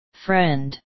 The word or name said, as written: friend